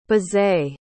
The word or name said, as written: bizet